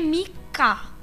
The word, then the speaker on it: Micha: Oh, nulisnya itu MICHA, bukan Mica aja. Jadi MICHA. Makanya bacanya itu sebenarnya Mika.